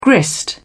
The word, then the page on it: grist: British English